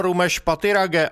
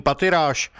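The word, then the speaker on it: Rumesh Pathirage: Vyslovoval své jméno Rumesh Patirage a vyloučil anglickou výslovnost svého příjmení Patiráš.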